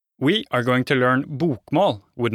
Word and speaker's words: bokmål: The first one is called bokmål, pronounced bokmål.